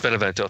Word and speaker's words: Benevento: And I like Marco Benvenuto, I think I said his name right, the keyboard player. – Benevento, thank you.